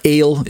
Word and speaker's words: El: El or Ale, if you want to pronounce it that way, for the E part.